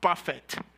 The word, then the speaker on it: Buffett: He was like Warren Buffett. How do you pronounce it? Buffett, thank you. Should be buffet.